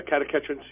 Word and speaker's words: catechins: A 2011 study in the American Journal of Clinical Nutrition showed that the catechins in green tea have a... Did I pronounce that right? – Yeah, catechins, yeah.